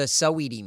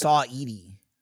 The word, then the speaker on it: Saweetie: I still feel like you're saying it wrong. – Yeah. I mean, that's just because you're looking at me maybe and going, there's no way he's saying it right. – Maybe it's Saweetie. – Oh, it is.